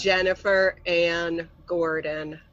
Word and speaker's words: Jennifer Anne Gordon: It's Jennifer Ann Gordon.